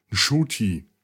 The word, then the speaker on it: Ncuti: Also der Name, geschrieben N-C-U-T-I, wird ausgesprochen Shuti. Mit einem nicht ganz stummen N quasi am Anfang.